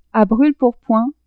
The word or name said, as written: a brule-pourpoint